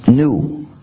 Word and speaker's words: GNU: Selon le dictionnaire, ce mot se prononce new, comme nouveau.